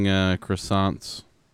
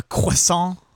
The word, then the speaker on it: croissant: Or it's like Americans eating croissants. – You mean a croissant? – I guess you could pronounce it like that if you're dumb.